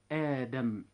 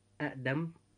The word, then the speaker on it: آدم: لاحظتم إخوة الإسلام أن كلمة آدم وكلمة إيمانا، لو قلنا أأدم وإيمانا لأصبح النطق صعبا، ولكن التخفيف جاء حتى يكون هناك سهولة في النطق، فنقول آدم وإيمانا.